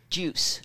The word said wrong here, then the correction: Guice: Geis. – Geis. – Yeah. – It's spelled G-U-I-C-E, but it's pronounced Geis.